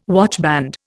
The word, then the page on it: watchband: Indian